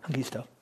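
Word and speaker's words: Hongisto: This is Hengisto, if I'm pronouncing that correctly. – Hengisto. – Sorry? – Hengisto. – Hengisto, okay. Yeah.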